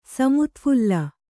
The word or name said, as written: ಸಮುತ್ಫುಲ್ಲ